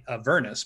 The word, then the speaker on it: Avernus: I say Avernus, because I was understood in one of their videos, they said it's like saying a furnace. Say Avernus, but I think when I originally read it, I would say it like you did, Avernus.